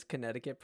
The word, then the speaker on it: Connecticut: Why is Connecticut pronounced like that when it's spelled Connect-it-cut?